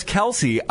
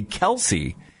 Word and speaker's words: Kelce: It isn't actually Kelce, it's pronounced Kelce.